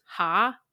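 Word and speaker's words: ha (ห้า): And he told me that the number five is pronounced ha in Thai.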